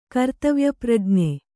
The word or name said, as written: ಕರ್ತವ್ಯಪ್ರಜ್ಞೆ